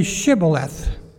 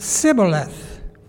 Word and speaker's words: Shibboleth: When he said, no, they said to him, then say Shibboleth. And he said, Sibboleth, for he could not pronounce it right.